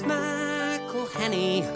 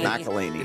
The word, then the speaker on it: McElhenney: It's McElhenney. What ways to massacre and mispronounce it, there are many. – McElhenney. – It's McElhenney.